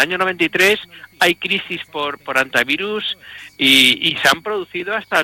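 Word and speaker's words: hantavirus: antivirus, por cierto, que se pronuncia en español, normal, sin la hecha aspirada, estamos hablando español, no estamos haciendo un término anglosajón.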